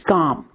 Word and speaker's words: gom: And so the Tibetan word for meditation is GOM, G-O-M. I have no idea what the characters in Tibetan look like for that. I just know that's how it's pronounced.